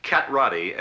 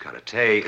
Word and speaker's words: karate: Cat Roddy, as you call it, correctly pronounced karate, is a defensive form.